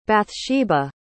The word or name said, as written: bathsheba